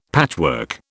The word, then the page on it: patchwork: British English